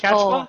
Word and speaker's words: Quechua: Quechua. Do you speak Quechua? Oh, no, it's actually pronounced Quechua. – Quechua. No, Quechua. Uh-huh. That's it. Yes.